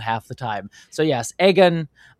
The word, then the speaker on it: Aegon: I love you, girl, but please stop calling him Egg Gun. It's Aegon.